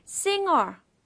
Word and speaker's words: singer: Now, singer sounds like the following words. Longer, finger, and hunger. 다 go로 끝나죠?